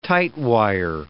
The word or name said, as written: tightwire